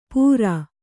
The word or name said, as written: ಪೂರ